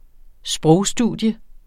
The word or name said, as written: sprogstudier